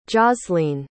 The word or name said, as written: jaslene